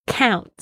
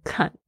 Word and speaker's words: count: 对，所以呢就是发现真的有很多学生他们那个 OU 的声音嗷嗷嗷就是念不出来，你就想那个中文的嗷好吗？ count 不要念成 cunt，真的哦，我去实验过，真的会让就是会出很多笑话。